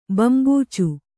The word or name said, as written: ಬಂಬೂಚು